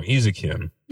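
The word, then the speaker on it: Eizouken: So as it turns out, the critics at the New York Times felt it was time to honor Keep Your Hands Off Izuka. I always say that wrong. Izakin, because it's a very artsy show.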